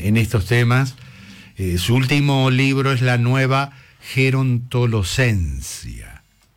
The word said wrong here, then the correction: Gerontolescencia: ¿Lo dije bien? – Gerontolescencia.